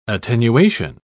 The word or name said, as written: attenuation